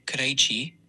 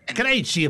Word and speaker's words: Krejci: El apellido checo, Krejci, se pronuncia de esta forma. Fonética aproximada en español, Krej. Y PA en checo, Verrián. Detalle por sílaba. Krej suena como Krej, con una E abierta y una J que se pronuncia como la IX en rey. – O sea. – Chi se pronuncia chi, con una H clara, como en chico, y una I larga.